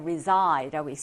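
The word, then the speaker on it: reside: 얘는 resident라고 읽었는데 얘는 reside라고 읽습니다. reside라고 읽어요.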